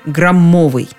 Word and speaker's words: граммовый: В них окончательно закрепилось ударение «граммовый».